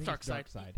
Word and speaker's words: Darkseid: I never know how to pronounce it. Do you guys? – It's Darkseid. – Is it Darkseid? Okay. – E-I-D.